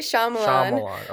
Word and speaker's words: Shyamalan: We say it Shyamalan. Okay. Yeah, we don't pronounce the Y, but, you know, there's some debate.